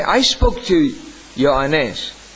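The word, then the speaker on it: Ioannes (Ιωάννης): Do you know what is the Greek pronunciation of John? Ioannes. Everything that we put as J in Greek and Hebrew will be Y.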